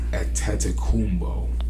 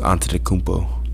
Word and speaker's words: Antetokounmpo: You just butchered his name, bro. It's Antetokounmpo. But no, Antetokounmpo. – Antetokounmpo. – Antetokounmpo. Antetokounmpo. It all sounds the damn same.